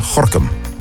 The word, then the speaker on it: Gorinchem: Net als Gorkum, waar je Gorinchem schrijft.